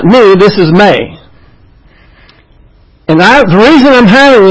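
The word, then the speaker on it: may (μή): Now you see, this is, in the Greek, that's pronounced may. That's a mu and an eta. And it's pronounced may. But over here in the English letters for this is me, M-E. But it's not pronounced me, it's pronounced may. This is a long A.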